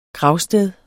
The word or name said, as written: gravsted